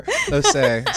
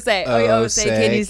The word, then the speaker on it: Osei: How do you say your last name? – No, keep that in there. Osei. So it's just like, Osei.